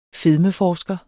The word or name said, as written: fedmeforsker